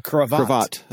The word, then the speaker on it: cravat: Isn't that a piece of clothing, a craviot? Craviot? – Cravat. – Cravat. Okay, yes.